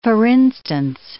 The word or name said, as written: for instance